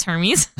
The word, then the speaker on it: Hermes: Shout out to Hermes for that. – Who is it? – Hermes? Yep. – You sure it's not Hermes? – I literally asked that just in case. It is Hermes.